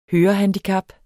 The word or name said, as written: hørehandicap